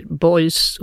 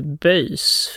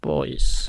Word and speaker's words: Beuys: Men jag är glad också att du säger Beuys och inte Böjs. För vi har diskuterat det där uttalet, jag och lite olika besserwisser människor på Sveriges Radio. Men, jag tänker att en fransos kan få säga Böjs. – Kanske, men jag tänker inte ändå är Beuys.